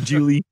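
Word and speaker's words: Gigli: Jiggly.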